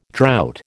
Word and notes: drought: American English

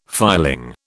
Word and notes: filing: British English

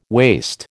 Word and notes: waste: American English

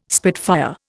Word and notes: spitfire: Indian

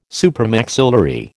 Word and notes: supramaxillary: American English

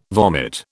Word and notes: vomit: British English

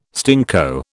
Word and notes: stinko: British English

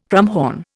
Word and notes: krummhorn: Indian

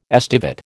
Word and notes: estivate: American English

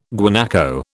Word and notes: guanaco: British English

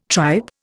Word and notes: tripe: Indian

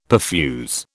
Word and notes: perfuse: British English

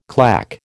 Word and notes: claque: American English

